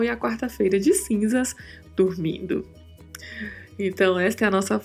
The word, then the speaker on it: durmiendo: dormindo tendo bastante cuidado com a pronúncia do R com o som de vibrato e de pronunciar claramente o yendo. Então falamos dormindo, dormindo.